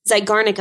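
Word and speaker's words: Zeigarnik: Is this, how do you say it? Garznick effect? – Zygarnik. – Zygarnik effect. Okay.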